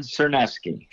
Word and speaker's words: Czerniewski: Don Cherneski.